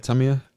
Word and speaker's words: Tamia: Is it Tamia? Is that yeah. How you say it? – Tamia.